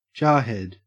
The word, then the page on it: jarhead: Australian English